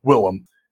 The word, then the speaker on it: Willem: His actual, yeah, his real name is William. He just pronounces it Willem.